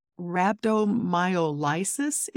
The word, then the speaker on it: rhabdomyolysis: Can you explain what rhabdomyolysis is? And I'm not even sure I'm pronouncing it right. – That's the correct pronunciation.